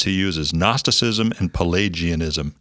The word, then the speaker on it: Gnosticism: Gnosticism, Pelagianism, and by the way, if you're listening to this and then you go and read it and you're looking for Gnosticism, you'd be like, well, they talk about this, but it's spelled with a G-N.